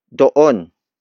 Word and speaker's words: doon: The next demonstrative locative pronoun is doon. Doon. Doon. Doon is spelled as D-O-O-N.